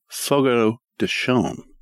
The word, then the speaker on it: Fogo de Chão: Not Fogo de Chão. – Chão? – Yeah. That's what the server told me. It's got like a Brazilian little squiggle over one of the letters and it makes it sound different.